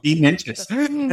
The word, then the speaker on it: Bean Ninjas: Did I say that right? I didn't even get the pronunciation. I was like, Beninjas? – Yeah, no, Beninjas. That's a play on the old school, you know, kind of boring traditional accountant was known as a bean counter.